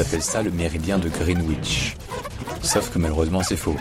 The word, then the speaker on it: Greenwich: Parce que l'orthographe anglaise indique que ça devrait se prononcer Greenwich. Sauf que ça ne dit pas du tout comme ça. En anglais, ça se dit Greenwich. Greenwich. Voilà.